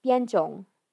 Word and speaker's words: Bianzhong: The bells that are pretty prominent in the beginning are these Chinese chime bells called Baizhong. And Bianzhong. I butchered that the first time.